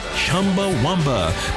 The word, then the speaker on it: Chumbawamba: Pronounce the band's name, Chumbawamba.